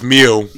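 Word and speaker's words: MØ: I think it's pronounced Mew. – Yeah. – Mew. – It's an O with a line across it diagonally.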